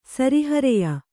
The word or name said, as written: ಸರಿಹರೆಯ